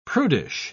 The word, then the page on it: prudish: British English